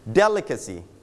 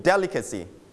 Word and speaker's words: delicacy: delicacy repeat after me delicacy straight on the first syllable